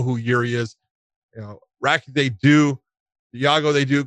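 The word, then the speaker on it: Rakić: I always say it right. Rakic is the way I say it.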